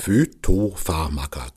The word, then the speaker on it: Phytopharmaka: Also wir unterhalten uns über Phytopharmaka. Habe ich das richtig ausgesprochen? Phytopharmaka, genau.